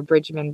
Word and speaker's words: Bridgman: So it's E-R-I-N-N underscore Bridgman, B-R-I-D-G-M-A-N.